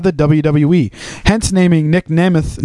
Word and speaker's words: Namath: It's pronounced Nick Namath, which sounds even more badass.